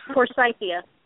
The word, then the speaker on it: Forsythia: Um, can anyone pronounce the next one? Forsythia. Thank you.